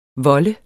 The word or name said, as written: volde